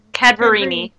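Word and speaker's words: Cadaverini: Yeah, I was going to say, I ship Furio Tigre and Viola Cavadari. I can't say her name. – Cadverini, thank you.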